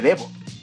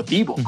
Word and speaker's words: Devo: Se avientan un cover de, mong de Mongoloid, de Devo, o Divo, como lo quieran pronunciar.